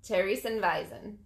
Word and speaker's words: Theresienwiese: It's a V at the end, but I mean W, but pronounced as a V. – I would say it's I don't know. – Theresienwiese.